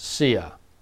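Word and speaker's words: Hsia: The critical part of his name is the last one, which I assume is pronounced Sia.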